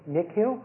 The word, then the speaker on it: Nikhil: Nick Hill? – How do you spell that? – N-I-K-H-I-L.